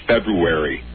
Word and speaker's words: February: Before I tell you that I'm really not looking forward to the next month of hell when you butcher the word February every day. – February. – There we go.